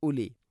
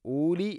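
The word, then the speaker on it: ഉലി (أولي): ഫഅതബിറു യാ ഉലിൽ അബ്സാർ, യാ ഉലി, മദ് മുൻഫസിൽ, നാലോ അഞ്ചോ ഹർക്കത്തിൻ്റെ ഖദർ നീട്ടാവുന്ന മദ്. അതുപോലെ തന്നെ ഉലി എന്നാണ്, ഊലി എന്ന് ഒരിക്കലും പറയരുത്. വാവിൻ്റെ മേലേക്കാണ് സുക്കൂൻ, അത് ഉച്ചരിക്കുകയില്ല എന്നുള്ളതിനെ സൂചിപ്പിക്കുന്നതാണ്. യാ ഉലിൽ അബ്സാർ, യാ ഉലിൽ അബ്സാർ എന്നാണ്.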